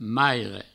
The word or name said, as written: maire